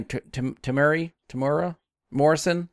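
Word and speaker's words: Temuera Morrison: He's hard to miss, but Tuamwe Morris, I can't never say his name, Tamura Morrison, the guy that plays Boba Fett, was Boba Fett in this series. – Tamura, you had it okay.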